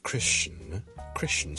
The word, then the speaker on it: Christian: 有 More than one acceptable pronunciation，例如基督徒，我哋 Christian C-H-R-I-S-T-I-A-N， Christian 好多时候咧，我就咁 Christian 就得啦。